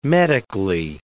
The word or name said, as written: medically